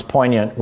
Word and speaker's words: poignant: And I'm from Michigan, and I've never, you know, which is the kind of same part of the Midwest, I never heard anybody pronounce poignant with a hard G.